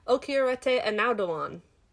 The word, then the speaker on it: Okieriete Onaodowan: it, I had I heard someone say it in a video because I, I could not have said it myself, but it is Okieriete Onaodowan.